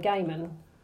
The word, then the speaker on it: Gaiman: I say Gaiman.